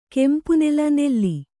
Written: ಕೆಂಪುನೆಲ್ಲು

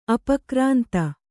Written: ಅಪಕ್ರಾಂತ

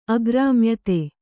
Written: ಅಗ್ರಾಮ್ಯತೆ

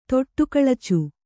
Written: ತೊಟ್ಟುಕಳಚು

